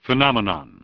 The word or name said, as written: Phenomenon